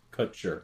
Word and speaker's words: Kutcher: Kutcher.